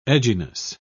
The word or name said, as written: edginess